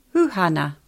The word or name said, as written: Huhana